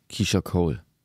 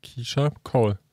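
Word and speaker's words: Keyshia Cole: Sean Paul, Keisha Cole. – Wow, hast du gut ausgesprochen. Ich war gerade überlegen, wie man sie ausspricht. Keisha Cole. Gar nicht so schwierig.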